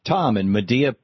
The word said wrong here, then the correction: Media: Pennsylvania. Am I saying that right, or is it Media, Pennsylvania, Tom? You're on the air with Senator Sanders. – Yeah, that's Media, Pennsylvania.